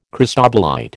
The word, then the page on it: cristobalite: American English